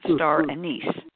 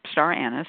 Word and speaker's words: star anise: Some people pronounce it star anise, but it's star anise in old-fashioned English.